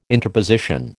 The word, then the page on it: interposition: American English